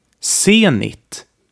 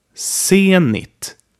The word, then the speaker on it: Zenit: It is pronounced just like a normal unvoiced S. Here are a few examples. Zenit. Zenit.